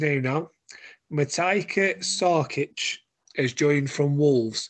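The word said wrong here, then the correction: Matija: He pronounces it as Matia, just saying now.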